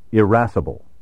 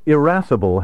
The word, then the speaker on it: irascible: Irascible may also be pronounced with a short initial I, irascible. Of the two pronunciations, irascible is the older and reflects the quality of the vowel in the word's Latin ancestor.